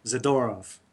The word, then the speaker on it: Zadorov: I spoke Russian for a number of years, and it's the second to last syllable you usually accent. So, Zadorov.